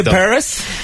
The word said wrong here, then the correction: Alvise Pérez: Alvise Pérez.